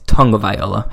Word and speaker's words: Tagovailoa: And then some guys that I have in my honorable mentions, Tristan Wirfs, Tua Tagovailoa. I think it's Tagovailoa. I think it's how you actually pronounce it.